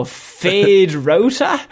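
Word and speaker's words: Feyd-Rautha: Oh, Fade Rauta? – Yeah, that's it.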